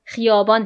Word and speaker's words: خیابان: این خدایبان را خیابان تلفظ می کردند.